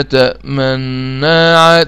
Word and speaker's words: تأمنا: أضم الشفتين للأمام ثم أرجعهما مرة أخرى. هو مشكلة الإشمام إنه شفوي، يعني مش هتشوف، يعني لا، ليس فيه تغير في النطق، هو أداء شفوي فقط. يعني هكذا: قالوا يا أبانا ما لك لا تأمنا. تأمنا، أنا عملت إيه؟ أنا لما جيت عند النون ضمت الشفتين ورجعت، أنا عملت كده، لكن طبعا أنت مش شايفني.